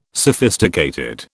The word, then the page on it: sophisticated: British English